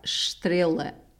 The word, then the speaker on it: estrela: And the pronunciation of star, estrela. E S in Portuguese is like estrela, yeah.